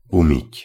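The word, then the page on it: umyt: Slovak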